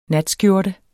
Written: natskjorte

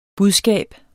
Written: budskab

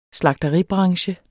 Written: slagteribranche

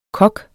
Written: kok

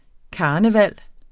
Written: KARNEVALET

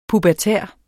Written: pubertær

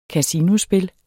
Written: kasinospil

